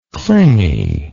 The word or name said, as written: clinginess